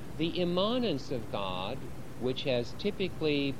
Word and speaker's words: immanence: I pronounce immanence oddly. Many people say immanence, and I don't need to explain why I pronounce it oddly, but it has an A in it, I-M-M-A-N-E-N-C-E, okay?